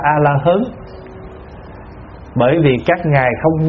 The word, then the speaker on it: A-la-hán: Cho nên thay vì A-ra-háp đọc là A-la-hán thì các cụ dạy là đọc A-la-hớn.